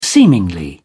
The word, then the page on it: seemingly: British English